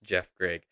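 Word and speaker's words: Geoff Greig: Again, his name is spelled GeoffGreig. So it's a little bit of a weird pronounce or spelling there, but it's pronounced Jeff Gregg.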